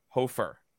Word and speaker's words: Hofer: So let's get to our uh, prospect of the week, and that is Joel Hofer of the. Mm-hmm. Did I mm-hmm. say that right? Yes. I did.